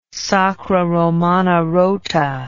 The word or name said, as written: Sacra Romana Rota